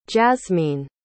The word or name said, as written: jazmyne